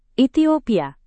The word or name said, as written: ethiopia